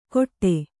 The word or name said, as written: ಕೊಟ್ಟೆ